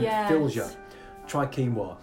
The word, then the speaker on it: quinoa: And for our listeners out there, quinoa is written quinoa, but pronounced quinoa.